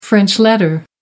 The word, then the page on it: french letter: American English